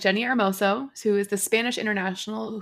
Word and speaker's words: Hermoso: Americans say Jenny Hermoso, but it's Jenny Hermoso because you don't say H's in Spanish.